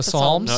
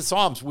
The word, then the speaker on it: Psalms: The P is silent, though. – She thanks that she is not like other people. Yes, Summer with the Psalms.